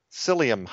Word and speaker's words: psyllium: will have bulk psyllium husks, and that's spelled with a P, P-S-Y-L-L-I-U-M.